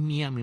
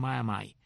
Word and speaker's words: Miami: No es Miami, es Miami. Y, o sea, la M-I final la pronuncian como Mai.